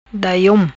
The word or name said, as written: Daiom